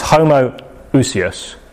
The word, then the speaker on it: homoousios: I'll pronounce it. I'm going to butcher the Greek pronunciation, but for the sake of it, it's homoousios. Okay, that's all one word, but it's coming from two separate words, homo and ousios.